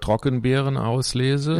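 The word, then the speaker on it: Trockenbeerenauslese: The word Trockenbirn auslese? Yeah. – Trockenbirnerschlösschen. See, I do lese. – Trockenbirn auslese. It's the German equivalent to the French word sélection de grain noble.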